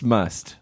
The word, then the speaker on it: musth: I think like it's pronounced Musth. Like, we don't have to say the TH at the end.